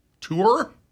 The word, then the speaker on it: tour: they say more like it rhymes with mower. – Tour? – Tour.